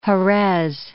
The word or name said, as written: Herez